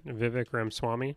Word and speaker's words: Vivek Ramaswamy: Vivek Ramaswamy.